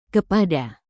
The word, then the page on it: kepada: Indonesian